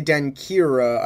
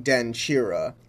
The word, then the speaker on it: Denkyira: Apparently, instead of being pronounced like ki, the combination of K and Y actually make a ch sound. So when I say denkira, I should have been saying denchira, and I should have been saying anoche instead of anokye.